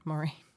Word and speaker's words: Moraine: So anyway, at Lake Louise, she said, why don't you go to Emerald Lake, since Lake Moraine, did I say it right? – Moraine is closed.